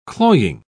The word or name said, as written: cloying